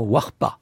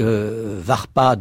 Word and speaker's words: warpa: Et cette expression qu'on retrouve sur une tablette cunéiforme qui a donné le mot urps, enfin qui est de la même famille, c'est l'expression warpa, V-A, qui s'écrit W-A-R-P-A, et varpa dai voudrait dire,